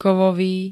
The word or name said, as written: kovový